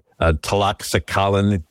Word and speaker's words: Tlaxcalan: Well, the Taxalan tribe that had been persecuted, and I know I'm not pronouncing that right, Tlaxcalan, D-L-A-X-C-A-L-A. – That's a tough one, man.